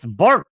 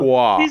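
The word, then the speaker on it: Barq's: It's bark. Hate that name. B-A-R-Q. Apostrophe S. How am I supposed to pronounce that? Bark. What is it? French now? Bark. Oh, what's wrong? – Barquois. – He spoke French.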